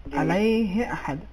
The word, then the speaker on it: علیہ احد: صحیح تلفظ یہ ہے علیہ احد، علیہ احد، اتنا زیادہ کھینچنا یہاں پہ ضروری نہیں ہے۔